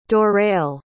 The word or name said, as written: dorrell